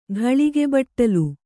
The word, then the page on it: ಘಳಿಗೆಬಟ್ಟಲು: ghaḷige baṭṭalu